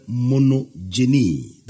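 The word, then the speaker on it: Monogenes (μονογενής): The only begotten is translated from the Greek word monogenes. That is M-O-N-O-G-E-N-E-S. I want to read it. I want to spell it again. M-O-N-O-G-E-N-E-S. Monogenes.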